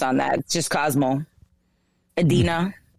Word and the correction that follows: Edina: Edina. – That's Adina. – No, it's Edina. – It's pronounced Edina.